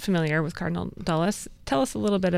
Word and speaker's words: Dulles: The name originally was Douglas, but they became Dulles in the pronunciation. Of course, the G is often aspirated in Irish.